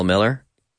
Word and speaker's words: Millar: I'm Kyle Miller is the name on Instagram. E-M-A. I'm Kyle Miller. K-Y-L-E-M-I-L-L-A-R.